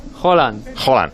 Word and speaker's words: Haaland: Os digo cómo se dice Haaland. Holland.